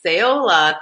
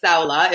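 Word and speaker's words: Saola: I think I called it the Saula through 80% of the interview before I finally asked Barney if I was pronouncing it correctly, and he politely told me I couldn't be pronouncing it more incorrectly. So I learned to pronounce it the Saula.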